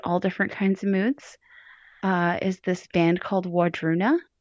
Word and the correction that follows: Wardruna: And what they do is Wardruna. – Wardruna. – Wardruna.